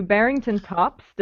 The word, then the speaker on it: Barrington Tops: Well, Johny took us up to Barrington Tops. Did I say that correctly, Johny? – Yeah, that's correct. Yeah.